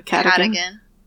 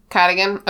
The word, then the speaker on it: Cadogan: How do you pronounce this portrait's name, everyone? – I do. Cadogan. – Cadogan.